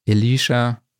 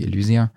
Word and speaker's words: Elysia: Ableton, Magix mit Sequoia, Lavo, Sennheiser, Neumann, RME, Adam Audio, Head, Elisha, wie es ja, glaube ich, richtig ausgesprochen wird, aber alle sagen natürlich Elysia.